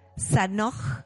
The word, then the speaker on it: Zajonc: De verdad, si tienen la oportunidad de encontrar un libro de él, no eh, no se pierdan leer, se llama Arthur, se llama Arthur Zanoj, se escribe Z-A-J-O-N-C. Él es un físico que ha escrito muchísimos,